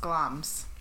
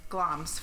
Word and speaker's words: Glamis: So anyways, Glamis Castle, and it's pronounced Glams. – Oh, really? That yeah. I did not know. – I read the pronunciation later because I was like, I don't want to butcher this. And I butchered it anyways. But Glams. So from here going forward is Glams.